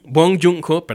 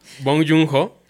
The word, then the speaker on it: Bong Joon-ho: Dirige Jung Bong-ho. Bong Joon-ho, perdón.